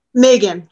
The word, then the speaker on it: Megan: Megan, like the month of May.